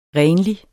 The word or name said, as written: renlig